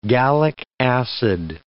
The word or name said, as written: gallic acid